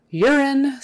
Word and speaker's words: urine: Urine, that first U now is pronounced with kind of a Y in front of it. U-R, ur, ur, ur. So Y-E-R, ur, even though it's spelled U-R. Urine. And even though there's an E on the end, that is still a short I sound. Urine, urine, urine.